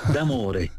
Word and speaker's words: D'Amore: Y entonces, mi apellido se pronuncia así como se lee, D'Amore, Bruno D'Amore.